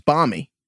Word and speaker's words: balmy: Do you pronounce the L in balmy? It's balmy.